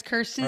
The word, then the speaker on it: Kirsten: I've spent my whole life getting called all the other things besides Kirsten.